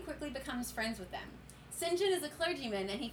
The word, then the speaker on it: St. John: Which St. John is pronounced is not pronounced. It is spelled Saint John.